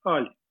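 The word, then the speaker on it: Hull: Французькою кажуть Аль. – Аль. – Аж не вимовляють. Ага. Аж Аль. Кажуть Аль. – Ага, окей. – Аж не вимовляють. Угу. Ну фактично, якщо вимовити місто на англійський манер, назву міста на англійський манер, ага, то це був би Хал.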